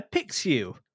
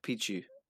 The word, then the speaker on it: Pixiu: It's spelled P-I-X-I-U. Pixiu? Is that about right? – Yeah, or isn't an X like a ch sound? So is it like a Pikachu? It's like a Pichu.